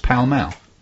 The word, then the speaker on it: Pall Mall: Do you know why it's pronounced Pall Mall? – No. – Because Pall Mall is French.